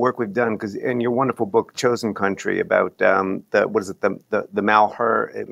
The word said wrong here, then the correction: Malheur: I'm mispronouncing it because I never took French. – Malheur.